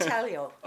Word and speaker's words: intaglio: It was just intaglio and... – Is that how you pronounce that? – Intaglio? – That's what I say. – I think it's intaglio.